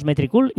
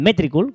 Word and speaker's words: Metricool: Que de hecho siempre le llamamos Metricool y lo siento amigos, eh, ellos le llaman Metricool con el acento en la E